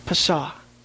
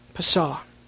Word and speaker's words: Pesah: And in order to help us remove any understanding that we have of it, I'm just going to pronounce the Hebrew word rather than the English word, Passover. Pesah. Pesah.